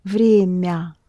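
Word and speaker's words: vremya (время): Yes, the difference between ma and mya is actually only in the ma, ma, ma is hard and vremya, mya is soft. The vowel sound is ah, but the m is pronounced soft. Vremya, mya. So it's ma, mya. A makes the consonant hard, ya makes the consonant soft.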